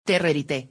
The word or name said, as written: Terrerité